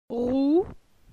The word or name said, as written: Rou